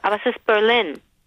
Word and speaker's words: Berlin: Du weißt, wie Deutsche Berlin aussprechen im Englischen, ne? – Berlin. – Nee, die sagen Berlin. – Ah, die sagen Berlin. Berlin. – Ja, weil sie denken, aha, im Deutschen ist es Berlin. – Ja. Also muss ich es im Englischen bestimmt umdrehen. – Ja, hätte ich auch tatsächlich vermutet. Ich finde, dass das auch eine gewisse Logik hat tatsächlich, weil im Englischen diese kurzen Wörter gerne auf der ersten Silbe betont werden.